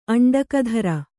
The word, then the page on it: ಅಂಡಕಧರ: aṇḍakadhara